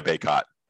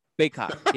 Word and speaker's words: Bacot: Armando Bacot. – Bacot.